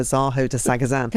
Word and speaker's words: Zaho de Sagazan: Zaho de Sagazan. – Can I just say amazing French pronunciation there?